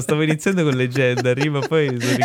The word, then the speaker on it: Legendary: non sapendo dove mettere l'accento l'hai messo su tutte le vocali leggendo legendary. no, è legendary.